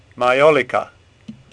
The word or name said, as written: Majolica